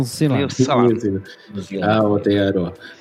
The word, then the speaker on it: Aotearoa: Oye, aquí encontré cómo se dice maorí, es Aotearoa. – Aotearoa.